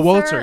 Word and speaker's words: Wolitzer: Oh, Wolitzer, yeah.